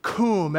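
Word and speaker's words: cum (קום): The first one is Q-U-W-M. Q-U-W-M. We pronounce it cum. Everybody say cum.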